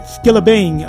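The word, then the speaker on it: Skillibeng: And before that, you heard SARS along with Asake, WizKid, and Skilla Beats. I think that's how you say it. Let me see if I said it. Skilla Bing. Oh, man. Sorry about that.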